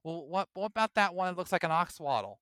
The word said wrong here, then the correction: axolotl: No, it's an axolotl. You just butchered that.